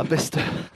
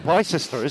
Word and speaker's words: Bicester: It's spelt Bicester, isn't it? – It is. – Which initially caused massive confusion on my part.